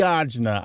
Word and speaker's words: agita: So usually the way you said it was ajna? – The ajna. – I love it with the article ajna. – In there. Usually these days it is spelled A-G-I-T-A, but you might see it as arjna.